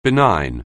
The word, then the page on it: benign: anglais